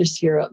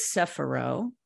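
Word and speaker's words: Sefirot: If I can If I can interrupt you for a second, and first of all, you pronounce it different than I learned it, but you're probably doing it in the correct original Jewish term, but I learned it as sephiro, right? The S-E-P-H-I-R-O-T-H, right? – It's actually Hebrew.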